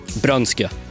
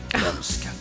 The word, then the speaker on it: Brunskog: Vi säger Brunskog, det du säger? – Brönske. – Brönske, så ska det vara.